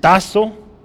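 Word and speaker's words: tazo (τάσσω): Esta palabra es tazo. Su pronunciación la pongo abajo. Tazo y significa dedicar. Es la palabra que usó ahí, se han dedicado, ¿no? Entonces, en griego así se escribe y así se pronuncia. Sale tazo con dos S.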